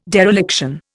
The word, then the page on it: dereliction: Indian